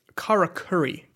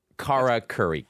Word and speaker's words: Karakuri: Karakuri. Sorry. Please. Can you fucking nail it down for a second? – You know what? Say it one more time. Can you say it phonetically so I can write it down? I have a note card. – That's K-A-R-A. – Okay. – Curry, K-U-R-I. – Kara Curry. Got it.